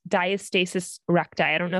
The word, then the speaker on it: diastasis recti: So diastasis recti, you said it right.